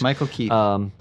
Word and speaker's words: Michael Keaton: Michael Keaton? – Yes, you did. – Boy, suddenly my brain just tweaked. – Yes, Michael Keaton.